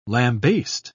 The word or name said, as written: lambaste